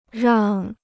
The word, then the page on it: 让: ràng